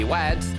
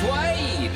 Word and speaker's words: Wade: It's not really Wads, it's Wade! But Wade doesn't rhyme with dads.